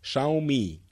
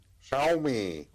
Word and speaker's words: Xiaomi: Euh, qui, et il y a des gens qui nous ont envoyé des enregistrements de euh, la manière dont on prononce Xiaomi. Alors, je vais essayer de reproduire. C'est Xiaomi. – Xiaomi.